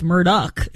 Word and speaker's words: Murdaugh: And it's really How to Get Away with Murdaugh, if you go by their own pronunciation.